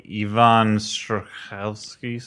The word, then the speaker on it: Yvonne Strahovski: She's played by Yvonne Strahovski. – Perfect pronunciation.